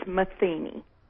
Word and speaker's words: Metheny: I'm so sorry to spoil your fun, but you know the proper pronunciation of that name. And you are sensitive about these things. – Mm-hmm. Yes. – Is Metheny.